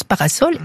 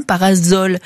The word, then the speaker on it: parasol: La question du jour pour terminer, c'est celle de Bernard qui se demande pourquoi, alors que parasol s'écrit avec un seul S, on prononce parasol et non parasol. Et idem pour asocial. Il me demande d'où vient cette anomalie. En réalité, cher Bernard, il y a quantité de cas où un S entre deux voyelles ne se prononce pas Z. Parasol vient de l'italien, c'est pourquoi on le lit comme en italien.